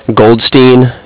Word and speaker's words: Goldstein: C-A-R-D-I-F-F as in Frank. And then your first name, P-E-T-E. The last name, please. – Goldstein, G-O-L-D-S-T-E-I-N. – Thank you, Pete. That's G-O-L-D-S-T-E-I-N.